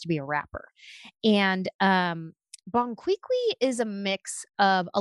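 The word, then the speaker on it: Bon Qui Qui: Bon Quiqui.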